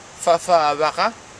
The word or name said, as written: fafawaka